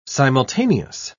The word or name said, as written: simultaneous